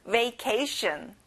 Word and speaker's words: vacation: 가운데 K에 강세를 넣어보세요. vacation. That's correct.